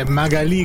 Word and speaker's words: Magali: Magali con l'accento sulla I, perché è una sì, che c'ha l'accento, ma che lo mette un po' dove le pare, perché sulla J non ci andrebbe, quindi...